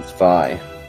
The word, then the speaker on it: Zwei: It's Zwei, so do Panzer Dragoon Zwei.